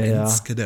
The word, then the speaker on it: Enschede: Der hat mich auch häufig korrigiert, wenn ich niederländische Orte ausgesprochen habe, sagte, das heißt ja, Enskede ja. und nicht ja, Enschede.